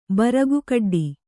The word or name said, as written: ಬರಗುಕಡ್ಡಿ